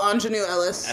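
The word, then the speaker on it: Aunjanue Ellis: Anjan Juana. Ellis. – Oh, Anjanue Ellis. – Anjanue Ellis. – I think it's Anjanue.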